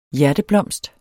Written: hjerteblomst

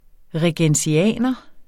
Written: regensianer

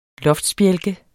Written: loftsbjælke

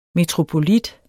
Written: metropolit

